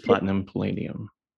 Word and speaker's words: platinum palladium: Platinum palladium?